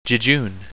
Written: jejune

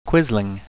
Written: quisling

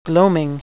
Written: gloaming

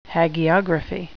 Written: hagiography

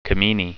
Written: Camenae

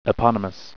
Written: eponymous

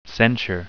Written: censure

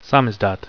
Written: samizdat

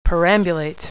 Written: perambulate

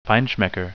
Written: feinschmecker

